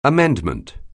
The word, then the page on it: amendment: anglais